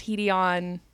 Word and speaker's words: Hedeon: So I think I naturally gravitate toward Hedion, but it is spelled H-E-D-E-O-N. It's pronounced Hedion.